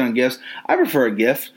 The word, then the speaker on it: GIF: How do you pronounce GIF? Is it GIF or JIF? Because if you say JIF to me, I'm kicking you dead in the fucking neck.